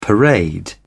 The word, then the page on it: parade: British English